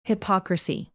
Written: hypocrisy

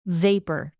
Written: vapor

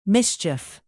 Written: mischief

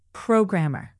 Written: programmer

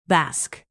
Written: bask

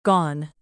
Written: gone

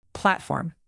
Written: platform

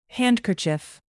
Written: handkerchief

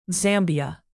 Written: zambia

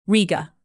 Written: riga

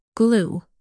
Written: glue

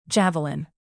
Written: javelin

